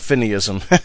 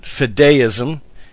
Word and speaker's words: fideism: Now, by the way, some people pronounce it fideism, and that's because it's based on the Latin word fide or fidei, but for some reason I've always pronounced it fideism, and I think either one of those will work.